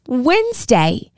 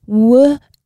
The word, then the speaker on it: Wednesday: Wednesday. Wednesday. It is spelt a little bit differently to how we say it.